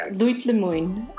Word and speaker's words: Litli Min: It's pronounced Litli Min. – Litli Min. Okay, very good.